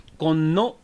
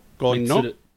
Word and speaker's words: Konno: Guys, we're back at Ichikaya Chocolate Square and very excited to have on the show this week Mitsuru Konno. I'm the worst at pronouncing her last name. Was that right or was that wrong? – It's okay. It's pronounced like Konno. – Konno.